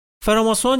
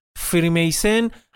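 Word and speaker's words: Freemason: فراماسون یا بهتر تلفظش کنیم فریمیسن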